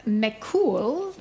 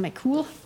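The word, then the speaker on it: Mac Cumhaill: Ähm, von den beiden Philosophinnen Claire McCool, wie sie übrigens ausgesprochen wird, bei den Engländern weiß man das nie, deswegen habe ich es mal nachgeschaut, also McCool und Rachel Wiseman.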